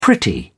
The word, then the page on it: pretty: British English